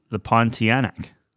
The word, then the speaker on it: Pontianak: The Pontiac. I don't know what that is. – Pontiac? – Yeah, Pontiac. – Yeah, I don't know. – Is that how you pronounce it? – The Pontianak. – Pontianak.